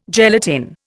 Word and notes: gelatin: Indian

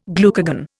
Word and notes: glucagon: Indian

